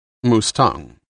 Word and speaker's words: Mustang: They are mostly found in the north of the country, up near the Chinese border, in what used to be—" "Mustang," Sam guessed.